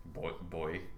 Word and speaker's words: buoy: Boy. – Boy. If people are not familiar with how British people pronounce that, it's boy. B-U-O-Y is pronounced boy.